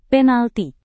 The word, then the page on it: penalti: Indonesian